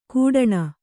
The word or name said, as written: ಗುಡಾಣ